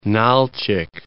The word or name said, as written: Nal'chik